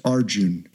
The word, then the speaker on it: Arjun: whose name is Arjun. It's usually spelled Arjuna. It's often pronounced with that A at the end is often silent.